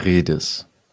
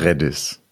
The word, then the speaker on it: Redis: Okay, und also der offizielle deutsche Aussprache, die Aussprache wäre Redis. Oder Redis. Oder Redis. – Ich glaube, im Englischen würde man eher zu Redis tendieren, im Deutschen würde ich eher zu Redis tendieren. Okay.